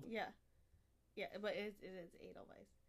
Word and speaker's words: Edelweiss: I mean, it is technically in America, probably Edelweiss, but because we don't pronounce the W like a V.